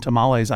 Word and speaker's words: tamal: But first of all, I notice you pronounced what I would have said tamale as tamal. Is that the correct pronunciation? – Tamal is the singular.